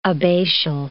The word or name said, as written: abbatial